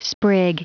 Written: sprig

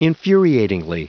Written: infuriatingly

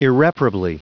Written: irreparably

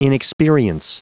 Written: inexperience

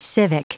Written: civic

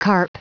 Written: carp

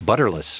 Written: butterless

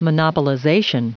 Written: monopolization

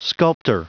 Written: sculptor

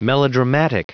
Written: melodramatic

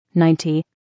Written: 90

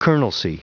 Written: colonelcy

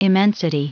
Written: immensity

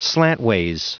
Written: slantways

